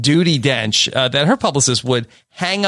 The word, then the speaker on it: Judi Dench: It's more dignified than Duty Dench or whatever. – Thank you. I think that be careful of the pronunciation there. – Judi Dench, like it's that's not like you called her Judi Dench.